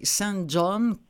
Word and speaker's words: St. John: Je rappelle le titre, Station Eleven, Eleven comme 11, d'Emily St. John, comme ça se prononce, comme John le prénom ou la ville, Mandel, M-A-N-D-E-L.